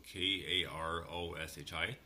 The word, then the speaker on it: Karoshi: Well, next up, we have a brand new band called uh, um, uh, Karoshi, so K A R O S H I. So I'm pronouncing it properly. So Karoshi with a song called Not My Style.